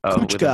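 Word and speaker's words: Kučka: And then it was Juraj Kuka, if I pronounced that correctly. – Kuchka, I believe goal.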